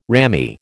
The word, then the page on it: rammy: American English